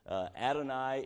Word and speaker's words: Adon: Adonai is God. Yahweh is God. And I wrote this word down because I knew I would forget it. What is this word? Adon is how it's pronounced.